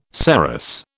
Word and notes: saros: British English

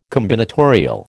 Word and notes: combinatorial: American English

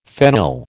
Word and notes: phenyl: American English

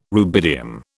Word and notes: rubidium: British English

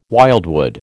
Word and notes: wildwood: American English

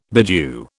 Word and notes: bedew: British English

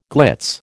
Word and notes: glitz: American English

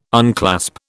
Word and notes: unclasp: British English

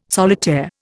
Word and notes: solitaire: Indian

